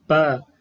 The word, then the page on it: p: Sanskrit